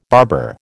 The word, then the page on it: barber: American English